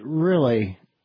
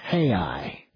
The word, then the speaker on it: real: Like the word for dollar down there is R-E-A-L. Well, I would pronounce that real. It's not real. It's hey-I.